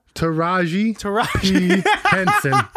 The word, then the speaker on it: Taraji P. Henson: You never say her name right, and I get so upset that you don't have the ounce of respect to learn her name. – What is it, Kareema? – Taraji P. Henson.